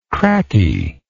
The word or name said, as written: crackie